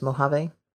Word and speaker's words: Mojave: I'm assuming as a native Californian that is the proper pronunciation is Mojave. – Yeah, that's how I learned. I used to go camping in the Mojave Desert when I was a kid. That's how we were always told the name.